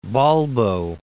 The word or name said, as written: Balbo